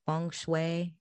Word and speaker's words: feng shui: Feng shui. Is that what it's, how it's pronounced in Chinese? – Yes.